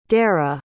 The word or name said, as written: dera